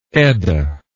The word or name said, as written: Eddaic